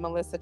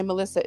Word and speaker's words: Malissa: So all my stuff just has my name, Malissa Chapin. Okay. And my Malissa is spelled with an A, by the way, though.